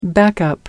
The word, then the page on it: backup: American English